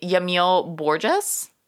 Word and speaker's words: Yamil Borges: So moving on to, my apologies if I'm not pronouncing this correctly, Yamil Borges? – That sounds perfect.